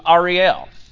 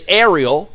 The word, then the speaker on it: Ariel: And it starts out, Woe unto Ariel. And, and that's the correct pronunciation. Uh, we would look at it and say Ariel, right? Uh, like the Little Mermaid. Uh, we would look at it and say Ariel. Um, but woe to Ariel.